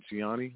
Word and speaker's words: Gianni: Oh, no, it's Gianni, like Gianni.